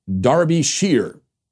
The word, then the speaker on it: Derbyshire: To add the correct sinister touch and not distract us with the comical pronunciation Derbyshire, please note that the name of this county is pronounced Derbyshire, with the emphasis on the first syllable.